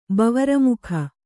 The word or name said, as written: ಬವರಮುಖ